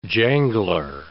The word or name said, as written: jangler